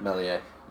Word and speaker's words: Meslier: And I think Meslier, I don't know if I pronounced that right, for Leeds, had a great season. – Meslier. – Yeah, that's the one.